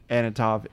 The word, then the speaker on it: Antonoff: Working with, I think, Jack Antonoff is what elevates this. If she didn't, Antonoff. – You said it right the first time. – Oh, I did. Okay. – Yeah, you sure did.